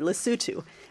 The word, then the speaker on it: Lesotho: And he mentioned one project in the tiny landlocked African country of Lesotho, which, if memory serves me, he said it correctly, Lesotho.